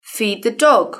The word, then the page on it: feed the dog: British English